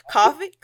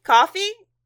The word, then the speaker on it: Ko-fi: Yeah, it's like lo-fi, but it's like they put coffee cups everywhere, so it's like coffee.